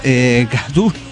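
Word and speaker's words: Cthulhu: Zulu, pronunciado Kadulu. Bueno, hay mil pronunciaciones. Cada uno lo pronuncia claro, como se le canta el huevo.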